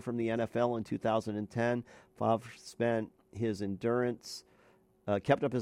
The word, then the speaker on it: Favre: How do you say that? Favre. – Favre is good. – Favre, I think, is how they pronounce it.